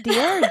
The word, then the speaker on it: DIA: Pocket Doll Studio announced that Dia, well, is it Dia or D-I-A? – It's like, I pronounce it Dia because it's like, do it.